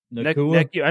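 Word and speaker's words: Nacua: Nakua.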